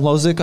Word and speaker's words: Hložek: Adam Hložek, I apologize for the pronunciation. – No, that's actually bang on.